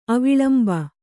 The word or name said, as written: ಅವಲಂಬಿ